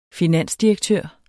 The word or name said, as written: finansdirektør